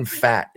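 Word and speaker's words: Pfaadt: Brandon Fatt is our next guy, and that is how you pronounce it. It's P-F-A-A-D-T. P-F-A-A-D-T. Brandon Fatt.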